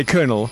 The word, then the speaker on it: colonel: I suppose because it's spelled colonel, yeah. – You say colonel.